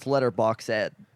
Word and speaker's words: Letterboxd: It's letterboxed with a D. I have to cut you off. – Yeah. – Nobody pronounces the D. – It's letterboxed. – Box apostrophe D.